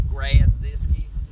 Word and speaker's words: Grodziskie: Oh, so let's go ahead and rate this beer, the Grodziskie. Did I say it right? You did. Grodziskie.